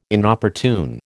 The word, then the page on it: Inopportune: American English